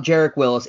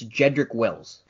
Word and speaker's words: Jedrick Wills: It's not Jarek Willis. It's Jedrick Wills.